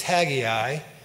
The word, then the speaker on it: Haggai: The correct pronunciation is Haggai. If I pronounce it as Haggai at some point during the message, please forgive me because I am Southern, and it looks like Haggai to me, even though it's pronounced Haggai.